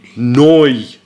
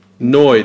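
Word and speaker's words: Nu: Do you know how to pronounce that? It's not new. – Noi. – Noi. This is noi.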